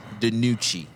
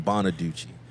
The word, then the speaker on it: DiNucci: Despite 4 turnovers from Carson Wentz, third-string quarterback, I got his name, y'all, Ben DiNucci, completed 21 passes. Syllables. Yeah, I did. I called him Bonaducci.